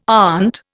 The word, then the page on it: aunt: Indian